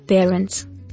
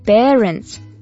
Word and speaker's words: parents: Слово «родители» переводится на английский как parents. – Parents? – Нет, parents – это попугаи. Хотя родители, безусловно, часто повторяют одно и то же, как попугаи. Все же не стоит так их называть. Правильно произносить parents.